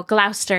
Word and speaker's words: Gloucester: How do you say that? – What? – Gloucester. – Oh, Gloucester, yeah. The C silent.